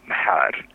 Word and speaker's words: Mhar: And I did the same thing with M-H, Mhar. So it's like you pronounce the M and the H as a consonant cluster.